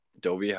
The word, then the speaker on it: Dovi: Dovi, how are you doing today? – I'm good.